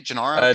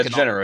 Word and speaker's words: Gennaro: Is it Gennaro? Is that how you say it? Gennaro. – Gennaro.